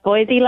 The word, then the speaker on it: Voisey: and it's Voisey like Noisy.